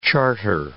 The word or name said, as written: charter